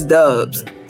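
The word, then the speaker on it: Doubs: Got another wide receiver who I think Romeo Dubs is a cold name because they don't like to pronounce Dubs, but it's Dubs.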